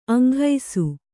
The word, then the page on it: ಅಂಘೈಸು: Kannada; aŋghaisu